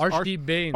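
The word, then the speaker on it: Arshdeep Bains: Archdeep Baines.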